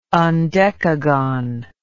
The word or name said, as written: undecagon